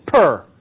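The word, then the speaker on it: pr: The way it's pronounced in Egyptian is per, and it means house.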